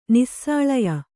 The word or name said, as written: ನಿಸ್ಸಾಳಯ